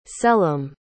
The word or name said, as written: selim